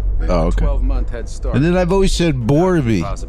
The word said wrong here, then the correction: Barbie: Is that how you say Borby? – Borby? – Oh, it's Barbie.